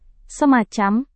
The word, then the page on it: semacam: Indonesian